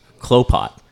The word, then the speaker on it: Klopot: It's Klopot. – Yep, rhymes with Gropot.